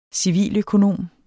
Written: civiløkonom

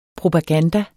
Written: propaganda

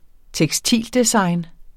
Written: tekstildesign

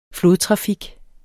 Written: flodtrafik

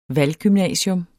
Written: valggymnasium

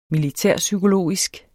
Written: militærpsykologisk

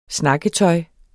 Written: snakketøj

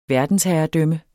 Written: verdensherredømme

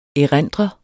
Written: erindre

